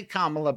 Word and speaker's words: Kamala: I've learned how to say Kamala, but a lot of people who want to insult her won't give her that courtesy.